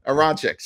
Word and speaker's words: Aronchick: Aronchick.